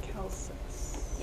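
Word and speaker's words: Kelsos: It's K-E-L-S-O-S Choices. – Kelsis.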